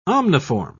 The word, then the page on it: omniform: British English